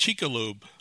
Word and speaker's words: Chicxulub: I can't pronounce the Chicxulub. – It's pronounced Chicxulub.